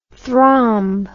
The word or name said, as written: thromb